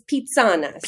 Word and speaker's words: Pizzana: Talk to me about Pisana. Is it pronounced Pisana? – You're very close. It's pizzana. – Pizzana.